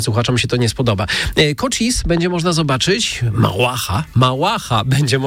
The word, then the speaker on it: Cochise: Oni tak akcentują się, zespół Cochise.